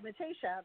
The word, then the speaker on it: Matasha: You said it, Matasha. That's my maiden name. That's beautiful. Is it um, Italian? Yes, it is Italian.